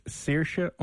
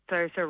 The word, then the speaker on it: Saoirse: So it's S-A-O. – Yeah. – I-R-S-E. – I. – Or S-E. – Oh, Saoirse Ronan? – Kind of like that, yeah, except it's not Ronan. She's almost as big as her, but it's Saoirse _ Long, L-O-N-G.